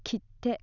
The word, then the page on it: kitte: Japanese